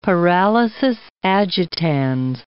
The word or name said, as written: paralysis agitans